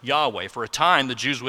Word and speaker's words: Yahweh: And so there's no vowels in the Hebrew as it was originally written, but it's often been pronounced Yahweh.